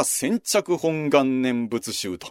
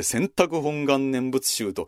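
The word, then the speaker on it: 選択本願念仏集: この読み方がね、浄土宗の場合は千着本願念仏宗と読むと、私前間違って千着本願念仏宗と読んでメルマガで、うん、それ違いますよと指摘されたことがありますので、千着本願念仏宗ですよ。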